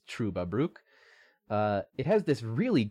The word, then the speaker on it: Trüberbrook: Uh, it's pronounced with an Uber as in Ubermensch, Trüberbrook.